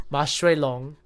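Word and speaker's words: Ma Shui Long (馬水龍): And a suite by a Taiwanese composer named, oh gosh, I'm gonna butcher his name, Ma Shui Long. – That's pretty good.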